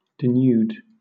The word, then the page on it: denude: British English